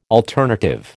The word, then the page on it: alternative: American English